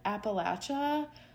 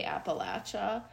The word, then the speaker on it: Appalachia: It's actually supposed to be pronounced Appalachia, but I feel like that's really hard with my Chicago accent, maybe. So I just want to acknowledge that I might say it wrong, and we're used to saying it wrong, but I've been corrected before that it's actually Appalachia.